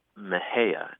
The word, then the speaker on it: Mexia: We have a funny town here called Mejia, and it's spelled M-E-X-I-A. – Mm. – And I think if you're an out-of-towner or a Yankee, you might be tempted to say Mexia, right? – Mm-hmm.